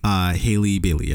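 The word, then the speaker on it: Halle Bailey: Yeah, is that her, how you pronounce her name? Hailey Bailey? – Hailey Bailey, yes.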